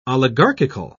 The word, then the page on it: oligarchical: British English